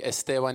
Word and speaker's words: Esteban: If you don't know me, Esteban, it's spelled E-S-T-E-B-A-N. I will go by Steve, but my wife hates that, so just learn how to say my name.